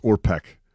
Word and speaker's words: Orpik: I believe that's pronounced Orpik. – Orpik, yes. I'm sorry.